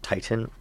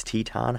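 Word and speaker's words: Titane: It is pronounced Titan with an E at the end, and that is how my American self originally just said it must be just Titan. It's Teton.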